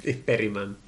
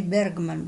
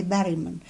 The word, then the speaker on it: Bergman: Berryman. – Yeah. – Yeah, okay. – They Americans say Bergman, but we say Berryman.